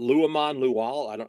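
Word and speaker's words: Luamon Lual: Which one of those four or any of the other new players, whether it be, you know, Nate Caddy or, is it Luamon Luwal? I'm probably mispronouncing that. Yeah. Did I get it right? – Yeah. No, I think you got that right.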